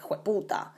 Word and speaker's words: hueputa: So in Costa Rica, of course, they made it kind of their own and they pronounce it slightly different. So it's more like hueputa.